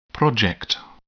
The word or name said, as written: project